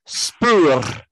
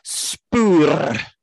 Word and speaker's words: Spoor: Called Spur. – Oh, yeah. – Do you want me to do that again? Spur. – Oh. – It's spelled S-P-O-O-R.